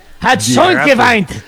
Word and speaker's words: schon geweint: Déjate a schon geweint. Creo que así se pronuncia. Esto quiere decir ya chilló la rata en alemán. ¿Estamos bien? Dirate. Hat schon geweint. – Muy bien. Impecable tu pronunciación.